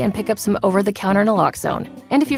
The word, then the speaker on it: Naloxone: For the record, it's Naloxone.